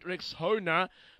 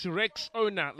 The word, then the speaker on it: Rexona: A friend of mine called it Rexona, but guys, it's Rexona, like Rexona, Rexona.